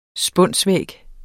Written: spunsvæg

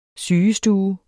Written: sygestue

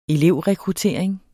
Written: elevrekruttering

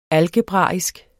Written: algebraisk

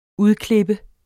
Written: udklippe

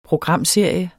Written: programserie

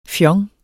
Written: fjong

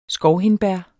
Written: skovhindbær

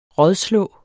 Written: rådslå

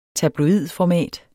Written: tabloidformat

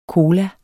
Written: cola

